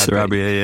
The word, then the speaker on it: Sarabia: Sarabia, yeah.